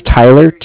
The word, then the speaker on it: Tyler: If you just put down that my name is Tyler, T-Y-L-E-R. – Mm-hmm. – I'm calling with Cardiff. It's spelled C-A-R-D-I-F-F, as in Frank.